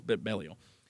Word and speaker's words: Belial: I'm going to say Belial the rest of the way out, but in Japanese it's Belial, just wipes the floor with everybody.